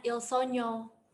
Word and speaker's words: Il Sogno: Judulnya Il Sogno, tapi cara bacanya Il Sogno. – Hah? Il Sogno, itu bahasa apa? – Bukan Il Sogno, Ho. Il Sogno yang diambil dari bahasa Itali.